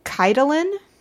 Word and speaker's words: Caitilin: I think it was Keitelin. It's spelled like Caitlin. It's spelled C-A-I-T-I-L-I-N.